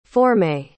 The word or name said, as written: formée